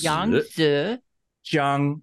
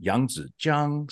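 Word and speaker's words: Yangzijiang (扬子江): Jiang is straight across. The tone is one. Tone number one. So it's Yangzijiang. Yangzijiang. So it kind of, your voice goes down and up high. Yangzijiang is how you say it. And I would not be a good Chinese American if I did not stop and explain to everyone that that is the correct way to pronounce it.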